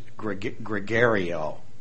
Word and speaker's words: gregoreo: And the Greek word here, if you ever wanted to do a study of your own, is gregario, the way we would pronounce it, like G-R-E-G-O-R-E-O, gregario.